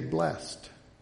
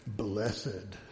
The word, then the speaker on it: blessed: They don't say blessed. They make it even more religious sounding by saying blessed, right?